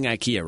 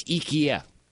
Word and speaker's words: IKEA: We've been pronouncing IKEA wrong. – Really? – Yeah, apparently. – Well, how you say it? – Apparently it's IKEA.